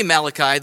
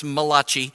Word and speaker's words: Malachi: And it says this, and I say Malachi, those of you of Italian persuasion would pronounce this Malachi. I get that, I understand. And you gotta say it like when you say it's Malachi, you gotta have your hand, yeah.